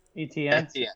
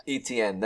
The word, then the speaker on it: Etienne: Um, Travis Etienne. – Etienne, thank you.